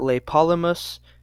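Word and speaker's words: Tlepolemus: Otherwise, um, how other versions go is that Lepolemus with a silent T at the beginning, the son of uh, Heracles and Astyache, uh, this daughter of Phylos, just some Thesprotian king, founded the principal cities on Rhodes.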